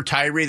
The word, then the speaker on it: Tyre: And, you know, Justice for Tyree, the guy's name was T-Y-R-E, and they pronounced it Tyree.